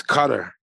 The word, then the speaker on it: Qatar: For those who pronounce it incorrectly, Qatar. – Yeah, that's how I say it because I speak American. – It's Qatar.